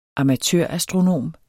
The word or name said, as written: amatørastronom